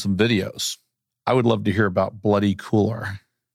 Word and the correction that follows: couloir: Couloir. Couloir. Couloir. Couloir. – it's the, it's the French word couloir.